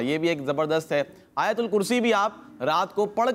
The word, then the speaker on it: آیت الکرسی: یا کے اوپر بھی زبر ہے, یا کے اوپر زبر ہے, تا کے اوپر پیش پڑھنی ہے, آیت الکرسی. تو اس کا تلفظ بھی درست ہونا چاہیے.